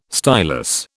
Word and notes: stylus: British English

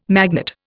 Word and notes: magnate: Indian

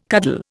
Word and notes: cuddle: Indian

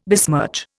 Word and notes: besmirch: Indian

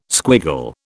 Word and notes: squiggle: British English

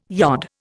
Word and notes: yod: Indian

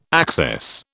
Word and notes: access: British English

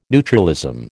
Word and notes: neutralism: American English

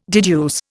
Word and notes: deduce: Indian

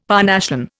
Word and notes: Parnassian: Indian

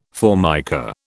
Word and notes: Formica: British English

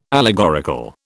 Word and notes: allegorical: British English

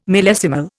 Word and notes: millesimal: Indian

